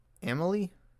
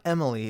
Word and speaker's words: Amalie: A-M-A-L-I-E. Why can't I pronounce that? Amalie? It's not Emily. It's like Amalie.